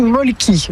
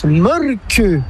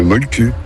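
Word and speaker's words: Mölkky: M-O-L-2-K-Y. Alors maintenant que vous savez l'écrire, encore faut-il savoir le prononcer. – Ça s'écrit Molki, mais ça se prononce Molque. – Le Molque. – Plutôt un E qu'un E, mais pas un U.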